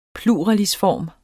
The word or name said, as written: pluralisform